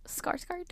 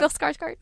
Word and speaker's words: Skarsgård: Oh, Skarsgård? – Yeah, there we go. – Bill Skarsgård.